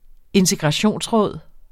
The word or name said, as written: integrationsråd